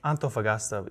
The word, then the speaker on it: Antofagasta: He is 18 years old and a senior at Spanish Fork High, and has recently been called to the Chile Antofagasta mission. Did I say that right? – It's Antofagasta, yeah. – There we go. – Close enough, yeah.